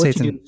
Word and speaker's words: seitan: Everybody's making this plant based chicken, plant based fish, plant based this with seitan. I hope I'm pronouncing mm-hmm. it right. But anyway, and it is. It's seitan.